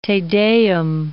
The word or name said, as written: Te Deum